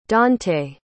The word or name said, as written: daunte